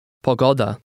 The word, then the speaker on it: pogoda: In Polish, the next to last syllable of a word is always pronounced with the greatest stress. Try to read number 3. – Pogoda.